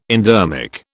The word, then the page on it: endermic: British English